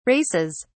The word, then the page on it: races: British English